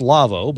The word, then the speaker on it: Lawo: If you want to go to the Lavo website, L-A-W-O, it's a German name. It's pronounced Lavo, but it's L-A-W-O.